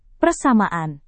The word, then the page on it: persamaan: Indonesian